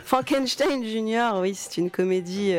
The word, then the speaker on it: Frankenstein: Il insiste pour qu'on prononce son prénom Frankenstein parce qu'il veut se distancier.